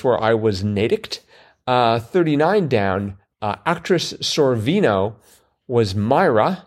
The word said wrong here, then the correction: Mira: Mira. – Mira. Mm-hmm. And again, just the fact that you corrected my pronunciation means you knew that.